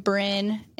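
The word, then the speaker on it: Brin: It's just my first name, Bryn, and then Amberly.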